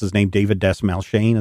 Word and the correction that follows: Dastmalchian: Is that how you say his name? – Dasmalkian.